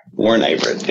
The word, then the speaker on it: Warren Averett: Am I pronouncing that right? A-V-E-R-E-T-T. – Warren Averett. Yep.